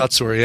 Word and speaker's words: Kotsur: Best supporting actor, is it Tony Kotsur? Is that how you say it? – Kotsur, yeah.